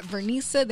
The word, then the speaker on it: Vernisa: And also, too, Y'all can check us out on Instagram at SpeakUpJV, and you can check out my personal page at Vernisa. That's V as in Victor, E-R in I-S-A.